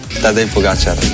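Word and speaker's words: Pogačar: Juanma dice que es aguda, mientras que otros es drújula, así que aquí tenemos cómo se dice de verdad. – Tadej Pogacar.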